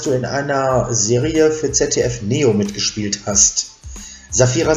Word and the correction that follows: Shapira: Saphira. – Shapira, Shapira. Genau.